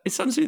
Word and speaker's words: Sun Tzu: Is Sun Tzu the correct pronunciation? I never... – Oh, yeah, Sun Tzu. Is Sun Tzu the art of war? – Indeed.